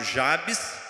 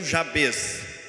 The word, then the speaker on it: Jabes: homem chamado Jabes, ou como alguns pronunciam, Jabez.